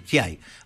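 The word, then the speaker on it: Giay: ¿Así se pronuncia? – No, ¿cómo se pronuncia la persona? – Giai. Se lo dice así. – Giai.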